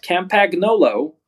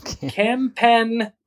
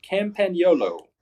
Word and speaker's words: Campagnolo: So Campagnolo, the fine Italian maker of bike components, is actually pronounced. It's not Campagnolo. It's Cam. Pan, and then you only live once. YOLO. Campagnolo. Campagnolo. There you go.